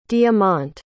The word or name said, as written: diamont